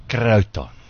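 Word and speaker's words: croton: The crotons. – That's it. – You got to say it like that.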